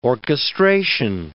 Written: orchestration

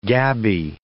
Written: gabby

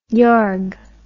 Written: yarg

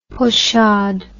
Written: pochade

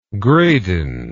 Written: gradin

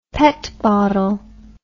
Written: pet bottle

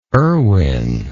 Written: erwin

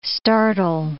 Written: startle

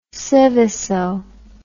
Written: Seveso

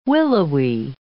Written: willowy

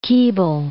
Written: Keble

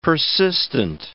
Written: persistent

